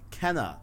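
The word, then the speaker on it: Kena: And I got a notification on my watch, Kana, that's how it's pronounced, Kana Bridge of Spirits is delayed until early 2021 on PS5 and PS4.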